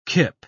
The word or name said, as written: kip